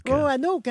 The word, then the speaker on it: Roanoke: Roanoke, hein, c'est ça, oui.